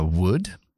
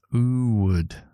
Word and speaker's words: would: Igual, recuerda que la W cuando tienes que pronunciarlo empieza con la U. Mucha gente tiene problemas con este sonido y es would. U. u. empiezas con la U porque es un W, es double you en inglés.